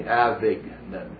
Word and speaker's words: Avignon: Avignon.